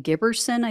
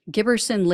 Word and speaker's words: Giberson: So 22-year-old New Jersey resident Larry Giberson, I think is how you say his last name, G-I-B-E-R-S-O-N. He traveled to D.C.